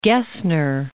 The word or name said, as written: Gesner